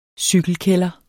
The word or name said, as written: cykelkælder